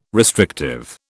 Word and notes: restrictive: British English

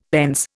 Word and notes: pence: Indian

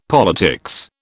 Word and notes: politics: British English

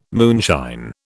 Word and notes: moonshine: British English

